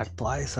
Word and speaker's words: Artois: Si te gusta la Heineken, ¿has probado la Stella Stella Artonis? ¿O cómo se pronuncia? – Artois, algo así.